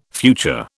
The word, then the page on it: future: British English